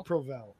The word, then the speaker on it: Provel: Was it Provol? Provol? – I'd say Provel.